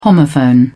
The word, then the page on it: homophone: British English